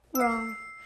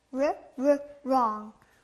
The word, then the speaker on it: wrong: Wrong. – 네, 아주 잘했어요. 틀린 영어로 wrong. 여기 앞에 ᄅ 소리 들리네요. 그럼 wrong 다시 발음해 볼게요. – ᄅ, ᄅ, wrong.